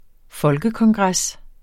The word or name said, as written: folkekongres